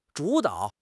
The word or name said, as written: 主导